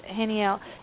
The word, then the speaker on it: Haniel: And I think one of the reasons why this particular angel, who I pronounce as Haniel, it's H-A-N-I-E-L, this particular angel is one that helps you create a more outgoing personality.